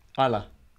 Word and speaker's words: Islay: Isla. It's not Islay. I think it's pronounced I was going to say Islay.